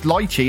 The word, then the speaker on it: lychee: Lemon and is it lychee? How do you say that? It's a bit posh for here in the Black Country. It's lychee, isn't it? That's it.